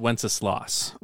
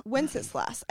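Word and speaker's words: Wenceslaus: I've always heard it pronounced as Wenceslas. – Wenceslas. I think that's correct.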